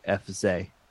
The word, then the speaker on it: FC: Apparently in Köln or in Cologne, the accent there means that FC is pronounced more like FZ, where there's a heavier emphasis on the second syllable.